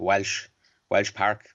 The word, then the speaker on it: Walsh Park: Yeah, like even I thought, which was quite genius with that game in 62, in it's spelled Walsh Park, W-A-L-S-H, but in our local accent, we call it Welsh Park, which is a Gaelic games ground.